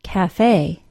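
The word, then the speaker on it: café: The word cafe not only does not have a silent E, but the final syllable is stressed and is pronounced with a long A sound, cafe.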